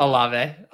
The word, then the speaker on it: Olave: Olave.